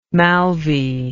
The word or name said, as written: maulvi